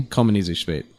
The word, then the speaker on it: Kommienezuspadt: Kommienezuspadt.